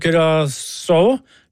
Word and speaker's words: Curaçao: Kommer en melding her fra Arne, som sier navnet Curaçao, uttales akkurat slik på norsk.